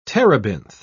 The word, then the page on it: terebinth: British English